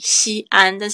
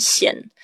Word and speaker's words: 西安: 很铿锵有力，西安，但是外国人看到西安，他们都想要念成县，因为就是就是把这个声音连起来就对了，这样子，对啊。